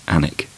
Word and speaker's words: Alnwick: such as Alnwick, which is actually Annick.